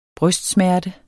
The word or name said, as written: brystsmerte